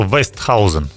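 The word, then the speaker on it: Oosthuizen: Тяжелые были какие-то вот такие ощущения, очень мы волновались, переживали, потому что Томас Остхейзен, да, вот так верно звучит его фамилия, ну, она в разных транскрипциях. – Правильно, как он сказал сам, что Вестхаузен. – Вестхаузен. – Вестхаузен, я тоже читал, но правильно говорит, что так, Вестхаузен.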